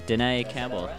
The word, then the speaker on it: Denea Campbell: I'm joined by Jenny Rube and Denea Campbell.